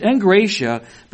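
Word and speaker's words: engratia (ἐγκράτεια): It comes from the Greek word engratia. It's spelled E-G-K-R-A-T-E-I-A. And it's spelled, pronounced though, without, with an N. It's pronounced engratia, but it's spelled E-G-K-R-A-T-E-E-I-A, meaning self-control or temperance.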